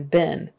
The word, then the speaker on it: been: And that been is pronounced B-I-N as if it were sit, fit, been. B-I-N. Been. I know it's spelled B-E-N, but you don't say it been. That will give you away. It's been. Been. So would have been. And that W-O-U-L-D is pronounced just like W-O-O-D for wood, like firewood, burn the wood.